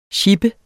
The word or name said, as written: sjippe